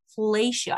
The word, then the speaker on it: fellatio: And last time we talked about cunnilingus or eating pussy, and today we're talking about fellatio. I always am super conscious of how I pronounce it. Do I pronounce it correctly? – You pronounce it wonderfully. Very sexy, in fact.